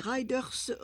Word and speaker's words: rider: Connaissez-vous le terme « rider » R-I-D-E-R, prononcé « rider » R-A-I-D-E-U-R.